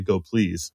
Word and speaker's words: gopls: Maybe the most important thing I learned from the talk is the correct pronunciation of Go PLS is apparently Go Please.